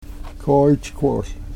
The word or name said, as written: kooychkwol